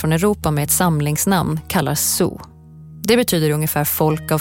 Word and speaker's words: Sioux: Och det där Nadowessu var ett långt ord att uttala och då tog fransmännen fasta bara på den sista stavelsen, Sioux, och gav den fransk stavning, Sioux.